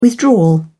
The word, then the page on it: withdrawal: British English